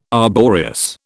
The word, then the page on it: arboreous: British English